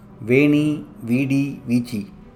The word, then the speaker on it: Veni, Vidi, Vici: This week I would like to share a popular Latin phrase which is pronounced Veni, Vidi, Vici.